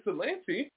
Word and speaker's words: Ypsilanti: It's Ypsilanti. – Oh, it's Ypsilanti.